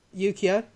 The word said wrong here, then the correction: Yukiko: Yukiko? – Yeah.